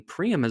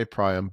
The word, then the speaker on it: Priam: So, okay, on Priam, I typically say Priam, but I agree with you that one should just pronounce them confidently and not worry too much.